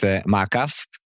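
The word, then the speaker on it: Ma'akaf: Now I said it in the Hebrew accent, Ma'akef. This is how we call it in our Hebrew accent.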